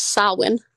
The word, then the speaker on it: Samhain: That word is pronounced Samhain.